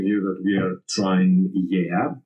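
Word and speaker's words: Igea (Γαία): Oh my God, I love how you pronounce this. I always said Gaia. – Yeah. It's, of course, in Latin characters, it's Gaia, but for me, this is why it's amazing to have you here. Can you please pronounce it one more time? – So it's Gaia, and Gaia is the ancient name for planet Earth.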